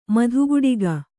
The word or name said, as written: ಮಧುಗುಡಿಗ